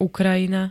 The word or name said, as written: Ukrajina